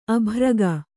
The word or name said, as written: ಅಬ್ಬರಿಗ